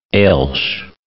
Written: Elx

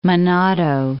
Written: Menado

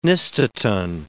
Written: nystatin

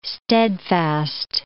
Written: steadfast

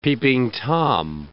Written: Peeping Tom

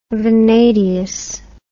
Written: vanadious

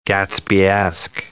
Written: Gatsbyesque

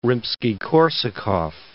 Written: Rimsky-Korsakov

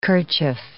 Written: kerchief

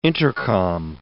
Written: intercom

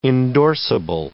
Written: endorsable

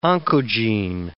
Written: oncogene

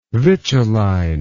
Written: vituline

